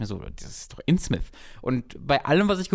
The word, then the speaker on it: Innsmouth: Innsmith? Innsmith, genau. Das übrigens Innsmouth genannt wird tatsächlich.